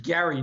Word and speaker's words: Gerry: It was named after this guy named Eldridge Gerry. Note the hard G there, not the soft G, right?